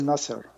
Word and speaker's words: Nasser: Gamal Abdel Nasser, yes.